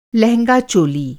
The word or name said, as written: लहेंगा-चोली